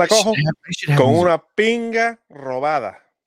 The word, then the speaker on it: robada: Robada. – Robada. You have to roll your R's. – You got to roll that R.